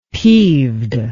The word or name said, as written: peeved